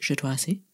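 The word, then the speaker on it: Ju/'hoansi: And this specifically deals with the Jatwasi, which is not spelled like that, but it is pronounced Jatwasi.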